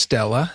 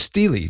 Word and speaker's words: stele: Again, you talk about the fact, first of all, explain to our friends what the word steel, S-T-E-L-L-E, means in the world of archaeology. – Yeah, so sometimes it's pronounced Stella, sometimes Stell, sometimes Steely, depending on if you're what side of the pond you're you're writing and speaking from. Which I like Steely, even though that's more of a British pronunciation, I believe.